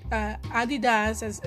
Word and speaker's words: Adidas: And they even, Adidas is how they're pronounced in Europe,